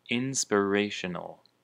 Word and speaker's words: inspiration: The word inspiration can be translated as 灵感, and the emphasis is on the re, inspiration.